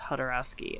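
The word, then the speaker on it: Jodorowsky: And then we have another graphic novel, The Inkle, Incal by Hodorowsky and Möbius. – Yes. – And that's J-O-D-O. So even though it's pronounced Hodorowsky, it's with a J.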